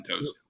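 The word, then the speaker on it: Toews: Jonathan, I don't know how to say his last name, Towes, who is a hockey player. – Jonathan Towes.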